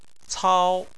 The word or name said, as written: cao1